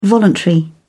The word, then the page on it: voluntary: British English